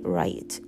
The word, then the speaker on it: right: あの R の発音なのでちょっと気をつけてください。でこれに right I know right っていうとだからさそれなみたいな感じになります。